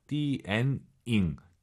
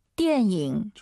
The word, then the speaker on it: 电影: 띠에닝, 니은 발음 들리면 안 돼요. 띠엔잉, 띠엔잉. 다시 들어볼게요. – 电影.